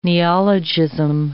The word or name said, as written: neologism